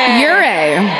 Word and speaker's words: Ouray: led by Chief Aure. – Ure. – Then why does it say our? Literally O-U-R-A-Y. Spell it like you say it, Chief Ure.